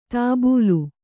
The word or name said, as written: ದಬ್ಬಳ